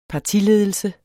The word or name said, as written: partiledelse